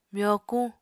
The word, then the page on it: myokuñ: Apatani